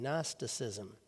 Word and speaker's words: Gnosticism: It's an ancient uh, way of thinking and religion and philosophy known as Gnosticism, spelled with a silent G at the beginning Gnosticism.